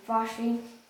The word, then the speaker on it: Vashti: Vashti.